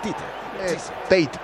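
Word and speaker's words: Theate: Se escribe Teate y se lee Tite. – Tite o Tate, sí.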